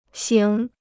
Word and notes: 醒: xǐng

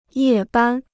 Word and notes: 夜班: yè bān

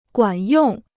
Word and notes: 管用: guǎn yòng

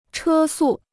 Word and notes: 车速: chē sù

